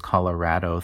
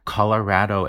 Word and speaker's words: Colorado: That's super interesting, and that ties into this Colorado versus Colorado thing. – Yeah! – Where Colorado is a much more Anglo pronunciation of this word, right? Because the vowel in Spanish is A. Colorado.